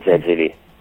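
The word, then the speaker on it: Zezelj: Tra l'altro è tratto, la sceneggiatura è tratta da una storia a fumetti di Daniel Zegeli, Zegeli, credo sia la pronuncia corretta, grandissimo fumettista e illustratore, e la storia originale si chiama Vania e Vania, ed sì, è di uguale intensità.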